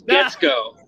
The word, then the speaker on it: Guetzkow: It's Guetzkow.